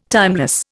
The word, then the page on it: timeless: Indian